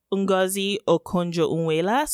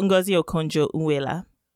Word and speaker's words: Ngozi Okonjo Iweala: So Ngozi Okonjo Iweala.